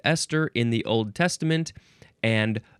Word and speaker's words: Esther: Capital E-S-T-H. This is an abbreviation for ester, which is our next word. Huh? Ester. It sounds the same as Esther from the previous episode, but it's spelled with an H because it's a name.